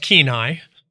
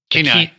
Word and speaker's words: Kenai: That would be pronounced Kenai. – Kenai.